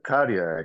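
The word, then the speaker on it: Kadhja: So you pronounce it Kadia, actually.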